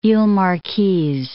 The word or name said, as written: iles Marquises